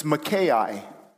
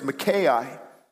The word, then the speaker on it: Micaiah: There was one prophet, his name, he's not popular really, it's not a book about it, it's Micaiah. Kind of sounds like Malachi or Micah, but it's spelled differently, it's Micaiah.